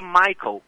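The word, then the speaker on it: Miquel: A grafia é estranha, M de Maria, I-Q-U-E-L, mas a pronúncia literalmente é Michael, Michael Brown.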